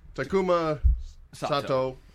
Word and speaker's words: Takuma Sato: Takuma Sato.